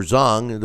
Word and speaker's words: Zhang: And it's going to boil down to if Zhang can catch him, or Zhang, the way it's pronounced, if he can catch him.